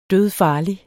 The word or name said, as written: dødfarlig